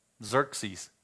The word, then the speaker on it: Xerxes: Xerxes, X-E-R-X-E-S. You know the document company Xerox. It has that Z sound, X sound.